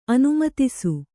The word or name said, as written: ಅನುಮಿತಿಸು